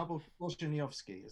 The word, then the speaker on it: Korzeniowski: I know this because I had to learn to say it. – Right. – Abel Korzeniowski. I can confidently tell you that's correct.